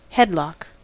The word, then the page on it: headlock: American English